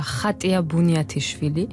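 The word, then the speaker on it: Khatia Buniatishvili: En géorgien, ça sera Khatia Bunyatichvili, mais comme je suis géorgienne et française aussi, oui. Même si je fais de petites fautes de français, en français on dit Katia Bunyatichvili parce que Khatia c'est vraiment difficile de prononcer. Mais donc c'est les deux, je suis libre à l'interprétation de chacun.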